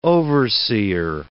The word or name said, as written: overseer